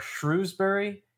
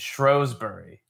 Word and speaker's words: Shrewsbury: So the two options are Shrewsbury and Shrewsbury.